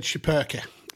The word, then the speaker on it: Schipperke: This is the, how would you pronounce this? A Schipperke? – So they said Schipperke on the show.